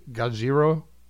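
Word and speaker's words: Gojira (ゴジラ): has been that when the Japanese say Gojira, mm-hmm. they're not mispronouncing Godzilla. – No, they're not. We actually are mispronouncing Gojira.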